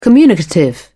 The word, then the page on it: communicative: British English